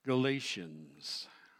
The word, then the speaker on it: Galatia: It's not pronounced Galatians. In the Greek, it wasn't pronounced Galatia in the time of Paul. That would have been a mispronunciation. It's Galatia. In the Greek, it is Galatia.